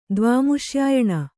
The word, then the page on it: ದ್ವಾಮುಷ್ಯಾಯಣ: dvāmuṣyāyaṇa